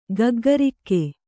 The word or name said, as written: ಗಗ್ಗರಿಕ್ಕೆ